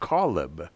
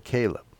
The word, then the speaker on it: Caleb: Well, number 13:6, as well as some other places, it was Caleb, and according to the Hebrew dictionary, it should be pronounced Caleb, but we pronounce it Caleb.